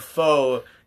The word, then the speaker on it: pho: It's not pho. It's pho, or however you say it. – Just, why do you not just admit that? – How do you say it? – Pho. – Pho. – It's like you're about to say the F-bomb, and then...